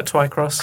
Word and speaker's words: Twycross: Is that how you pronounce the name of the town? – Yeah, Twycross.